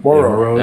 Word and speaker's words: Moreau: And so, you know, I think obviously Waller and Moreau are going to be the, or how do you say pronounce now? Moreau? Is that Moreau? – The, Moreau. Yeah, Moreau.